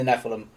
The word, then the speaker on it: Nephilim: And the Nephilim, uh, the Nephilim, I, I say the Nephilim. Nephilim's how you pronounce it.